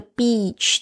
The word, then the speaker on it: beach: 大家特别注意一下这个 beach 的发音哦，有些人那个 e 发的太短，就变成好像是一个英文里面不好的字，所以呢，一定要注意一下这个 e 的长音要很用力的，然后两颊呢有一点点紧绷。